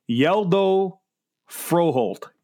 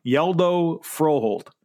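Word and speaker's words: Hjalte Frøholdt: Here's how you say it. I have the pronunciation. It's Yeldo Froholt. Yeldo Froholt. That's from Denmark. That's how you pronounce it. Yeldo Froholt.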